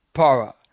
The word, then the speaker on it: para: First of all, para. That's a preposition. Para. P-A-R-A, para. The second Greek preposition is en, like an in, but with an E, en, E-N.